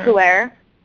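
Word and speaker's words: pu'er: and that's pure tea. I'm probably pronouncing it wrong. It's pure. – Oh, the puer.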